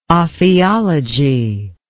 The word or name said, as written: ophiology